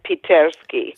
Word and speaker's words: Pitersky: Is it Siloviki and Pitersky? So, I don't know if I've got that right. – Siloviki and Pitersky. – Thank you.